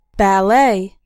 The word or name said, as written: ballet